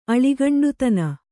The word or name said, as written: ಅಳಿಗಂಡುತನ